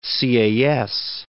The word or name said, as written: Sieyes